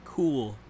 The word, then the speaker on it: Cuil: My topic was on something called Cool Theory, which I thought was named Quill Theory, considering it's spelled C-U-I-L, but it's actually, I found out, pronounced Cool Theory.